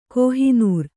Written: ಕೋಹಿನೂರ್

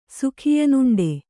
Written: ಸುಖಿಯನುಂಡೆ

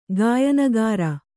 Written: ಗಾಯನಗಾರ

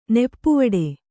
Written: ನೆಪ್ಪುವಡೆ

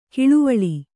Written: ಕಳವಳ

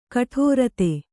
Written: ಗೌಡೀರೀತಿ

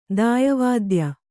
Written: ದಾಯವಾದ್ಯ

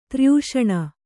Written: ತ್ರ್ಯೂಷಣ

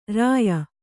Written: ರೂಯಿ

